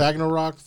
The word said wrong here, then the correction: Ragnarok: How do you pronounce that damn word? Ragnarok. There you go.